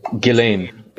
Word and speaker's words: Ghislaine: Ghislaine? – Ghislaine.